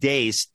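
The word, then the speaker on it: Deace: But and by the way, it's pronounced Dace. Don't ask me why.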